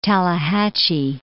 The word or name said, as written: Tallahatchie